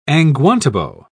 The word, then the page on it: angwantibo: British English